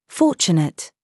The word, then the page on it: CHURCH: British English